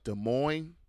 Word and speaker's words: Des Moines: Des Moines.